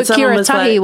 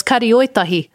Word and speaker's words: Karioitahi: I didn't know Kiratahi was Karioitahi.